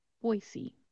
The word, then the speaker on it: Boise: When you see this city name, you might think it would be pronounced Boise or Boise, something around those terms, but instead it's pronounced Boise. Boise. So sometimes people will pronounce this word with a Z sound, like Boise. That's not how we're going to pronounce it. We're going to keep it as an S sound.